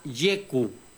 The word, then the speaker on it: YQ: Pero no, en la agencia ya nos dijeron que no, que se llama YQ, incluso YQ, porque es con acento en la U.